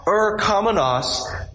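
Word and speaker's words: er kamenos (ἐρχόμενος): So you have "ha," and then for "coming one," you have it's spelled E-R-C-O-M-E-N-O-S, and the N-O-S means that it's a noun. So it's the coming one. Ha er kamenos, the coming one.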